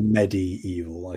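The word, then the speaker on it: MediEvil: Or Medieval, I suppose, is the official pronunciation.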